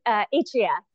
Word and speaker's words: Atria: So first, let me just make sure, Atria or Atria? Am I pronouncing that correctly? – It's Atria. I had to learn Atria. How to say it too. Atria, yeah.